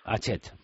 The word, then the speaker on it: Hachette: ¿Se pronuncia Hachette? – Hachette, vale.